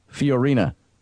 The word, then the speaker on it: Fiorina: And by the way, I've been mispronouncing Carly Fiorina's last name. Jeff Daugherty just corrected me. Fiorina.